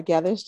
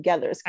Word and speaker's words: Gethers: First, my name is Laura Gethers. Just like you said, thank you so much for pronouncing Gethers correctly.